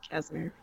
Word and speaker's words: Casimir: Yeah, Casimir. Yeah. – Casimir. And they've used the Casimir gates to travel across the universe, jumping through essentially, as you say, stargates or wormholes that these Casimir gates create to jump from star to star.